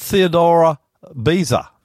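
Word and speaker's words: Theodore Beza: Now, today we're going to look at a guy called Theodora Beza. Now, did I say that right? – That's right.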